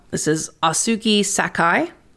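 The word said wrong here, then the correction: Ōsugi Sakae: That's Osugi Sakae, in case I'm totally butchering that.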